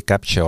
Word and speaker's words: Captur: millel ta baseeruma hakkab ja see on siis Renault Captur või Capture, oleneb, kuidas siis keegi hääldada tahab. ja baseerub ta siis loomulikult äh, CMFB äh, platvormil ja see oli siis tehtud juba enne valmis, kui Mitsubishi üldse selle alliansiga liitus.